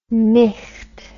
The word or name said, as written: micht